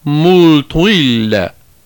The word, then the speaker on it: multum ille: The particular words referred to above by Quintilian are written out in full as multum ille. They appear in the third line of the Aeneid and were probably pronounced something like multuille.